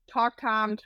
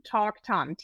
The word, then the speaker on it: Toktam: Yes, Toktam Thomas. It's like talking to Tom. Toktam, T-O-K-T-A-M.